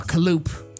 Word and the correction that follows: cantaloupe: Yes. – Or cantaloupe, as it's correctly pronounced.